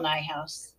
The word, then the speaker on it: Nijhuis: Michelle Nijhuis. – It rhymes with my house. – But does it technically, is that a real rhyme if it's Nijhuis and my house?